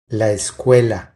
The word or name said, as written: La escuela